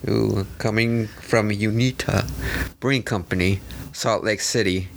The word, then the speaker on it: Uinta: Why do you gotta say it like that? – I don't know how to say it, bro. – Unita? – Unita? Uinta. Unta. – Uinta. – Uinta Brewing Co.